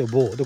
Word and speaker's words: Beau: で、ボーというのは BEAU と書いてボー。で、これあの主人公のキャラクターの名前ですね。えー、主人公がボー・